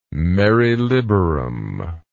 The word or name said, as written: mare liberum